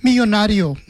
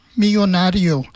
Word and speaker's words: millonario: Millonario. – Okay. – Like it's a millionaire. – Yeah. – And it's millonario. So the double L's, you pronounce it as yeah. So millonario.